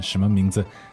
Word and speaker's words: 什么: 什么. – So that's second and neutral, right? – Yeah. – How do we spell that? – 什么. S-H-E-N.